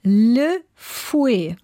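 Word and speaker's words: Lefuet: Ach so, und dann französisch ausgesprochen. – Ja. – Le Fouet. – Ah, okay, Le Fouet, ja. – Also mit T, ne, mhm, was man mhm, aber nicht mhm, mitspricht.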